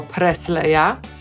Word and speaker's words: Presley: To samo jest z Elvisem, którego my po polsku czytamy jako Presleya, a po angielsku to Presley. Taką wymowę usłyszymy tutaj, ale może się też zdarzyć, że usłyszycie wymowę przez S. Presley. Nie udało mi się znaleźć wywiadu,